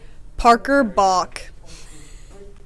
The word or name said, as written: Parker Bach